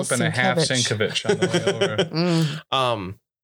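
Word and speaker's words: Sienkiewicz: And like looking at thing, like it's pronounced Sinkevich. – Sinkevich. – God bless you. Or Sinkevich. Because the K-E-V in the middle for the pronunciation is all capitals.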